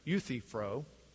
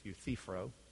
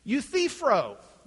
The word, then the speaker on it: Euthyphro: which is interesting because a lot of them seem to pronounce it Euthyphro, but that's not the way you'd pronounce it if they read Greek. It's Euthyphro. Emphasis is on the center syllable. Euthyphro.